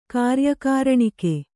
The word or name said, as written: ಕಾರ್ಯಕಾರಣಿಕೆ